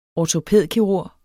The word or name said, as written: ortopædkirurg